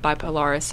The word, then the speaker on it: Bipolaris: So we're testing four fungicides with bipolaris.